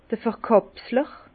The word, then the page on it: d'r Verkàpsler: Bas-Rhin; Herrlisheim